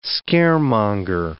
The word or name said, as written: scaremonger